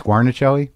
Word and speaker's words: Guarnaschelli: Alex Guarnaschelli? – Perfect.